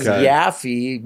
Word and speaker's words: Yaffee: Oh, and then Yaffee, Y-A-F-F-E-E, is very uncommon. So I get Marc, you know, on my posters, Marc Waffle, Marc Yahee, Marc Yahoo, Mr. Yippee. Yeah.